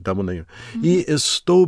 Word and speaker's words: Estou: Estou. – Estou. So listen to how she kind of goes, at the end of that word.